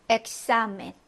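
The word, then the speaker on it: examen: 엑사멘 해서 앞에 음절 기역 받침 그리고 뒤에 음절에 시옷 자음으로 오는 겁니다.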